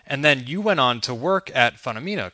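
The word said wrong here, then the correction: Funomena: Yeah, the pronunciation is phenomena, like phenomena, but, Okay.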